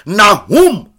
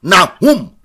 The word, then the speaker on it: Nahum: Nahum, Nahum, Nahum is spelled N-A-H-U-M.